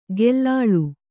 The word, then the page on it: ಗೆಲ್ಲಾಳು: gellāḷu